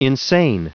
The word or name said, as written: insane